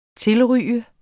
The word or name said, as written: tilryge